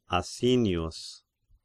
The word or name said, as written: Asinius